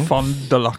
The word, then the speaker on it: Fond du Lac: Fond du Lac.